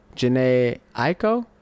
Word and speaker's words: Jhené Aiko: They had uh Jhené Aiko.